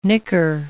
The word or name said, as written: nicker